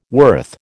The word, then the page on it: worth: American English